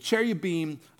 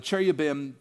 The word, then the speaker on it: cherubim: The cherubim, uh, the cherubim, both are okay pronunciations.